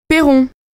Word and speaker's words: Pairon: M-E-R-I-E-U-X. – Nom de jeune fille? – Perron. P-A-I-R-O-N.